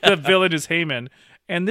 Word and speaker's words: Haman: So one of the elders at the Jubilee is he's an anti-Semitic Jewish elder, and he calls on, I can't remember how it's pronounced, Haman or Haman? – Haman. – Haman, right? Yeah.